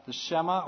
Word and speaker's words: Shema: And in the Jewish tradition, this is known as the Shema or the Shema. I'm not quite sure how to pronounce that. S-H-E-M-A.